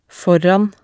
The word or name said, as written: foran